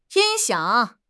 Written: 音响

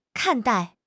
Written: 看待

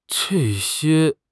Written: zhe4 xie1

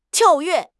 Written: 跳跃

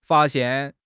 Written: fa1 xian4

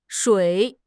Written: Shui3

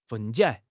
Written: 分解

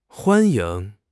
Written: huan1 ying2

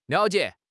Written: liao3 jie3

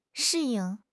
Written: shi4 ying4

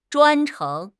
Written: 专程

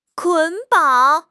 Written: 捆绑

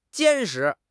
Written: 坚实

